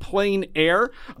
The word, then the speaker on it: Plein Air: And for those of you who have been listening to this show, you might have heard me pronounce it Plein Air, but it is Plein Air Washington Artists, P-L-E-I-N.